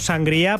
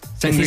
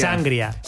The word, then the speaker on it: sangría: Bueno, vosotros decís sangría, ¿no? Lo pronunciáis mal. – Sangría. – Decís sangría. – Sangría. – No, pero es que le ponéis ahí el acento en la A, en la primera A.